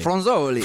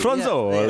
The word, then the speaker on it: Fronzoli: Um, tell us about uh, Am I saying this right? Because I want to say it properly. I feel like I should say Fronzoli. – Fronzoli. – Fronzoli. Yeah, yeah, that's it.